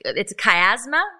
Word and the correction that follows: chiasmus: Is that right? Is that how you say it? – Chiasmus. – Chiasmus.